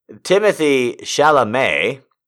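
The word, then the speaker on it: Timothée Chalamet: So his name is apparently pronounced Timothy Chalamet.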